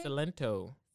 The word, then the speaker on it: Silento: Silento. Is that how you say his name? – Silento.